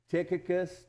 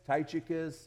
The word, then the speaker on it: Tychicus: Tychicus, Tychicus, so whatever you like.